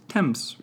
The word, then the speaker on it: Thames: Simply put, the river's name has always been pronounced Thames, with a simple T sound.